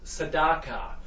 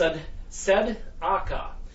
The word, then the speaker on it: tzedakah: It was the actual pronunciation of the word, which is tzedakah. The TS is like the tsunami sound. The T is a silent. It's tzedakah.